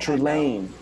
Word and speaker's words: Trelane: It's pronounced Trelane. – Trelane. – Times it can be tough for folks, but it's really actually very simple.